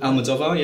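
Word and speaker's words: Almodóvar: Is that a pronunciation? – Almodovar, yeah, yeah, yeah.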